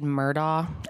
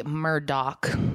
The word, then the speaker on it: Murdaugh: Their last name looks like it should be said Murdaugh, and I think I have even referred to it as that during this video. Like I said, half speeds, guys. It's actually Murdoch. That's how you say it.